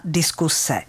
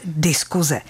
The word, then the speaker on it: diskuse: A spisovná výslovnost slova diskuse byla až donedávna diskuse. Akorát, že to nikdo nerespektoval a všichni pěkně jako vy moderně začali říkat diskuze.